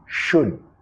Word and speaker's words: should: La L, sorda, muda, disculpa, no lo vas a pronunciar. Should, should.